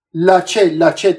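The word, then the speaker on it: Laxe: اولیور لاچه هست اسمش، لاچه تلفظ کردن در هم توی به اصطلاح کنفرانس مطبوعاتیش هم توی اختتامیه که صداش کردن اولیور لاچه صداش کردن.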